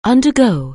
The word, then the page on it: undergo: anglais